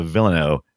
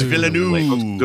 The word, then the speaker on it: Villeneuve: Dennis uh, Villeneuve, however you say his stupid name. – Denis Villeneuve. Villeneuve. – I think it's Villeneuve.